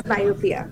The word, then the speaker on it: presbyopia: Okay, and previously we mentioned the word presbyopia. Did I pronounce it correctly? – Yes, presbyopia.